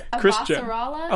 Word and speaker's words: Avasarala: Am I getting her name wrong again? – I think it's Avasarala.